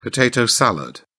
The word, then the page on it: potato salad: British English